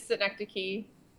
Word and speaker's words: synecdoche: Yeah, it is synecdoche. Some people don't pronounce it right, and it actually works out okay, because it opens up a conversation to explain what it is and what we're about.